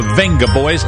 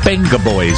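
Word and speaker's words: Vengaboys: Then on Friday, 3rd of May, songs on the way from Whitney Houston, Wonder Stuff and the Venga Boys. Sorry, the Benga Boys. It's a B, isn't it? You're supposed to pronounce it with a B.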